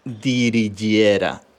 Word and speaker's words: Dirigera: Se escribe D-I-R-I-G-E-R-A pero se pronuncia digi, dirigiera, ¿vale? Según la gente de, de IKEA.